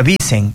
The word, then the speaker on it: Wissen: Uh-huh, obviamente pronunciándole sería Wissen, pero bueno, ya lo hemos tropicalizado acá.